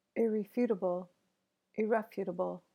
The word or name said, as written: irrefutable